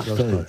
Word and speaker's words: Tolstoy: No es exactamente Tolstoy, es Tolstoy. – ¿Cómo? – Lo dije con Tolstoy, con A, una... – Embajador, lo dije así tal cual, Tolstoy. – Correcto, es una Tolstoy, es una A que tira hacia el O, pero que es A.